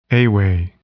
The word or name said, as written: ewe